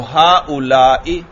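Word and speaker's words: هؤلاء: تو ہا اولائی، دیکھو آخر میں کیا ہے؟ حمزہ ہے، اور حمزہ کے نیچے زیر ہے.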